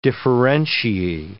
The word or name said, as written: differentiae